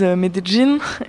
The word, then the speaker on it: Medellín: C'est difficile à prononcer en fait. C'est Là-bas, ils disent Medellín. En fait, c'est un petit mix entre Medellín et Medellín.